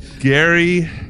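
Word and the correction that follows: Gerry: I think it's actually pronounced Jerry Battles, says that the incident occurred on December 26th, 2001.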